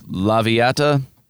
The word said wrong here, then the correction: Loviatar: which I'm pronouncing perfectly. Loviatar, sorry. So again, vote online.